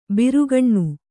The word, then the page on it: ಬಿರುಗಣ್ಣ: birugaṇṇa